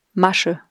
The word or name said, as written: Masche